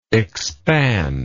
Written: expandibility

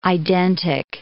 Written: identic

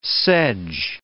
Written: sedge

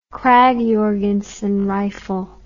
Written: krag jorgensen rifle

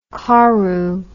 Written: koru

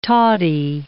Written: toddy

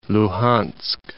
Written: Luhans'k

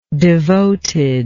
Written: devoted

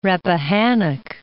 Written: Rappahannock

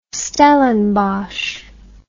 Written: stellenbosch

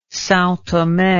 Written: Sao Tome

